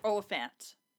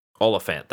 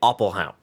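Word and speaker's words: Olyphant: This is one of my favorite Drew Barrymore performances, as well as, I always forget how to pronounce his last name, Timothy... – Oliphant. – Oliphant, that's right. – Elephant. – Exactly. I always want to say Oppelhaut.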